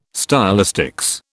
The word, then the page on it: stylistics: British English